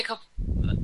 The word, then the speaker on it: Coppola: Coppola? – Yeah, Coppola.